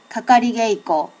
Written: kakarigeiko

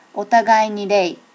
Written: otagai ni rei

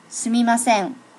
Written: sumimasen

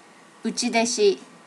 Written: uchideshi

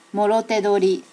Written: morote dori

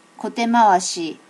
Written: kote mawashi

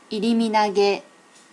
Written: iriminage

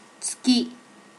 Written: tsuki

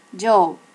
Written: jo

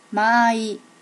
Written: maai

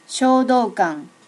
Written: shodokan

